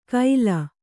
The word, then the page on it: ಕೈಲ: Kannada